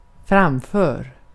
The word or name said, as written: framför